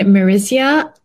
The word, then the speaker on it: Marysa: So I love this brand, Marisia. It's spelled Marysa.